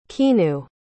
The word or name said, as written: kinu